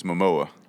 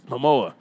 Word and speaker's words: Momoa: Jason Momoa, am I saying that correctly? – I think it's Momoa. – Momoa.